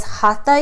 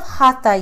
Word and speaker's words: Hatha: Now, when we think of Hatha yoga, spelled H-A-T-H-A, but pronounced like hot at the beginning of the word, it actually means force in Sanskrit.